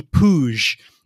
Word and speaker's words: Puig: They came insanely close to beating us in the playoffs, like terrifyingly close, thanks mostly to the incredible midfield play of Ricky Pouge, which I believe that's how you say it. It's spelled like Puig, but I think it's Splooge with a P.